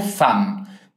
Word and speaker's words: femme: On reprend cette première partie en entier en faisant attention au mot femme. – Femme s'écrit avec un E, mais on le prononce comme un A.